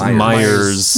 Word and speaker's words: Myers: Meyer Briggs is a daughter and mother duo. – While we're on the topic of mispronouncing things, you've said Meyer Briggs. – It's not Meyer? – It's Myers. – Myers Briggs.